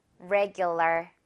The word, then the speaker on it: regular: 일단 레라고 시작하면 안 되겠네요. 레, 레. 혀 지금 다 아무것도 안 닿아야 돼요. regular. 그리고 마지막에도 R이 있다 보니까요. regular, 러가 아니라 ler. 이렇게 버터 발음 한번 넣어줄게요. regular.